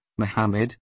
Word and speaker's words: Muhammad: Muhammad, Arabic, pronounced Muhammad, circa 570 CE-8th of June 632 CE, was an Arab religious, social, and political leader and the founder of Islam.